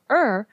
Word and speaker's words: Ur: It's only spelled with two letters, U-R, Ur, and it was near a river called Euphrates.